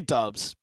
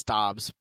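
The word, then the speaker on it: Doubs: I say Dubs, but it's Dobbs.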